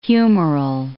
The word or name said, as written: humoral